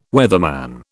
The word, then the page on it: weatherman: British English